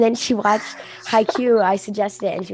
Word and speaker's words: Haikyuu: It's not pronounced Haikyuu. – Haikyuu, yeah, it is.